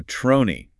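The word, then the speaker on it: tronie: But basically, a trony, and it's spelled T-R-N-I-E. It's a Dutch word, and it literally just means head.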